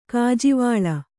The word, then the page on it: ಗಾಜುವಳೆ: Kannada